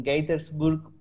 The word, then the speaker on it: Gaithersburg: Hoy es el pastor principal de la Iglesia Gracia Soberana en Gaithersburg, Maryland, Marcelo, dime si lo pronuncié bien, por favor.